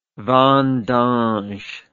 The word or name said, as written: vendange